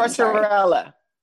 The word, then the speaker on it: Tortorella: So the three guest secret celebrities were Nico Tortorella. I can't pronounce his last name. – Tortorella.